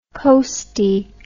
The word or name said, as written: coastie